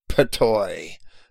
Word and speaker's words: Ptuj: But the modern Slavs pronounce it Petoi, P-T-U-J, actually.